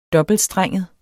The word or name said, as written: dobbeltstrenget